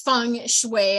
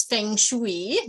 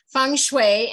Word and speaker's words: feng shui: So feng shui, and the pronunciation is something people struggle with a lot of times. You're pronouncing it perfectly. It is feng shui. A lot of times people see it and they think it's feng shui, but it's feng shui.